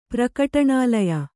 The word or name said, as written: ಪ್ರಕಟಣಾಲಯ